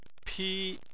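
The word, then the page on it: pi1: tone 1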